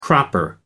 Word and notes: cropper: American English

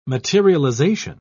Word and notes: materialisation: British English

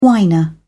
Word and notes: whiner: British English

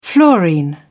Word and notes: fluorine: British English

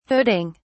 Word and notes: hooding: British English

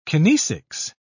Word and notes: kinesics: British English

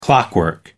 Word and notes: clockwork: American English

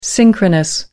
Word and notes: synchronous: American English

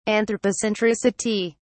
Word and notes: anthropocentricity: British English

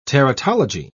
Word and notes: teratology: British English

